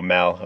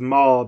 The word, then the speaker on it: Mal: Yeah, that's why I Dom says Maul, but I'm pretty sure Arthur at one point pronounces it Mal, or with a slightly different A sound. – Like Mallory, but Mal as in bad.